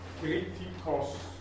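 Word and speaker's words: Kritikos (κριτικός): Betonung über dem Omikron, Kritikos.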